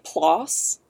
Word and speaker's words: PLOS: In a 2017 study published in the journal Plus One, but the plus is spelled PLOS, so like PLOS.